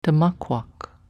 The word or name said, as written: tmakwak